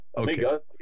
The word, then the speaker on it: Amigo: Well, Tim, you returned to music in 2001 via the formation of the blues funk band Amigo. Did I pronounce that correctly? It's how it's spelled. – Yeah. – Okay. – Amigo. Yeah.